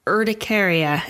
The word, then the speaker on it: urticaria: Should we jump in and just, what is urticaria? Am I saying that right? – Yeah, you're saying that right.